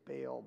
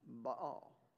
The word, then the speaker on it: Baal: Now, many of you say Baal, but in Hebrew, it's 2-syllable word, Baal.